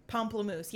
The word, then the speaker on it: pamplemousse: Pamplemousse, yes.